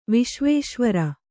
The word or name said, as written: ವಿಶ್ವೇಶ್ವರ